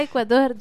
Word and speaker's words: Ecuador: Hai detto giusto, Ecuador. – Ah, io ho detto Ecuador. – No, è Ecuador giusto.